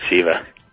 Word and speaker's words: Siva: Siva, I'm sorry. Did I pronounce it wrong? – That's right, Siva.